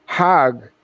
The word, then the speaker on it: Hajj: Now, Hajj is a pilgrim to Mecca. Hag is the Egyptian pronunciation.